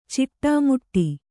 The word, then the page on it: ಚಿಟ್ಟೆಮುಟ್ಟಿ: ciṭṭe muṭṭi; Kannada